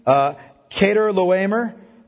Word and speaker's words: Chedorlaomer: We see those guys pretty quickly in Genesis 14, in the time of Abraham, an Elamite king, Chedorlaomer, if you don't pronounce it rightly. It's Chedor, even though the CH is a K in Hebrew.